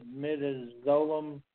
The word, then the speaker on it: midazolam: No, the mid midazolam.